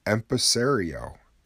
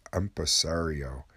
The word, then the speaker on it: empresario: And also earlier I struggled with the word empresario, I believe is is how you say it.